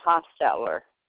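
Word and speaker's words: Hostetler: Okay, my name is Sarah Hosteller.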